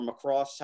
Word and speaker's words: Macross: I never watched Macross or Macross, however you want to pronounce it. The emphasis goes on the other syllable.